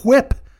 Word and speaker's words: whip: I can go cool whip. Whip. Why are you pronouncing the H?